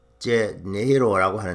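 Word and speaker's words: Rio de Janeiro: 리오데자네이로는 포르투갈 말로 발음으로 히오제네이로라고 하는데 인구 70만 명의 매혹적인 도시입니다.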